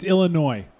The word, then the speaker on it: Illinois: It's Illinois.